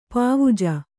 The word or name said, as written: ಪವುಜು